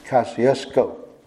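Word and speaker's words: Kosciusko: He had every kind of pronunciation you can imagine and can have ever heard, until I mentioned to him that there was a short O in Kosciuszko, and a long O at the end of it, Kosciuszko.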